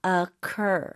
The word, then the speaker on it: occur: Occur. Occur. – 오, occur가 아니네요. – 네, 스펠링이 O-C-C-U-R이고 occur, occur의 강세를 주시면 돼요. – 아, 뒤쪽이 올라갑니다.